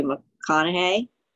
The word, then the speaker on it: McConaughey: Now I pronounced his last name wrong. Matthew McConaughey. Is that how you say it? Yeah.